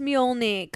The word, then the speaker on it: Mjolnir: But it's Mjolnir, guys.